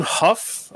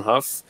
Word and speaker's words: Hough: But so this is directed by John Huff, I guess is actually how you pronounce his name. It looks like Hugh or Ho or Hogue or something like that, but apparently it's John Huff, British director.